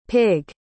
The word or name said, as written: pigg